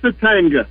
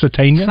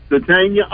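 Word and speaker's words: Sategna: Drop the G, though. Let's learn to say his name. – Isaiah Satanga? – Tania. It's Spanish. Satania. – Silent G. – Yeah. – Satania.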